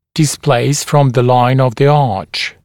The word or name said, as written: displace from the line of the arch